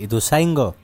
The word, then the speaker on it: Ituzaingó: Viste que lo dicen de las dos maneras. Acá ella pone Ituzaingó. – La bebida cola que le dimos le dio un resultado tremendo a ustedes. Ituzaingó, sí. – Porque se pronuncia así también.